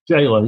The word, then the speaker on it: gaoler: Jailer, yeah. It's a soft V, it's jailer. – So it is pronounced the same way, jailer and jailer.